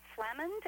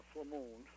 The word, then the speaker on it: Flamand: Your choices are Carol Curtis in Stand By for Crime, Pat in Manhunt, Sandra Lake in Crime Files of Flamond. Am I pronouncing that one correctly? – Uh, Flamand. – Flamand. Oh, it's French.